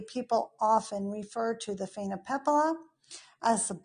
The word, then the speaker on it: Phainopepla: Okay, so I have the Phainopepla. I say that right? – Yes, okay. Perfect.